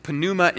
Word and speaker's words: pneuma: It's the word ruach in Hebrew and pneuma in Greek, and yes, you do pronounce the P in Greek.